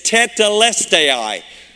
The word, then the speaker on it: tetelestai: It's tetelestai. Tetelestai.